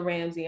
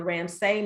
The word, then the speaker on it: Ramsay: Basically, they can just look up anything dealing with Coach Tina Ramsey. Like I tell business owners, you have to be Googleable so people can have access to you. So they can find me at Coach Tina Ramsay.